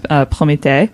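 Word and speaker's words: Prométhée: Well, the French version has basically like accents and all the E's, and it's pronounced Promethe.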